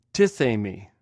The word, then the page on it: tiqhmi: Greek